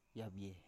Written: yavie

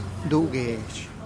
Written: du gesh

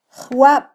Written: jwab

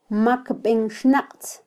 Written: makbenxnaqtz

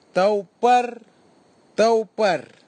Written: taupartaupar

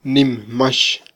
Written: nimmax

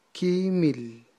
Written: kyimil

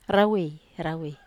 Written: rawe

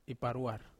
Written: paruar

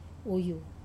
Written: wuyu

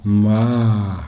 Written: mhaa

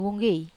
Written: wonggei

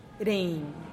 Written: reen

